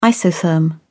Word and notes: isotherm: British English

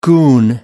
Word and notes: goon: American English

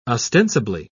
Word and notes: ostensibly: British English

